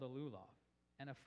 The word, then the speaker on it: lulav: And together, I'm going to butcher this word, you're going to know this, the lulav, I don't know how to say it, but yeah, I got an okay.